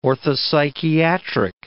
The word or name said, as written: orthopsychiatric